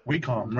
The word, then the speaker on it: Wacom: So do you, neither one of you, I know Agent 70 mentioned how you pronounce it, Wacom tablet? – Wacom, right.